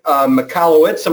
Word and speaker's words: Michalowicz: So, Mark, we've got Mike Michalowicz.